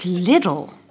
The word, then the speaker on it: Lidl: Lidl, like little. – But don't they say Lidl, the Germans? – They say Lidl because it's spelt little, which is L-I-D-L. Whereas Lidl would be L-I-E-D-L.